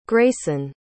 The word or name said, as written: grayson